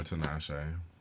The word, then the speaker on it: Tinashe: I feel like he's your Tinashe.